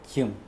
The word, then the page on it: jimw: Mokilese